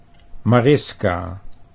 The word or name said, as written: Mariska